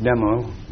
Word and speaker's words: Tummo: It's spelled T-U-M-M-O. It's pronounced Dumo because the D is only the letter T, substantialized, and it refers to the heat that you develop in your tum.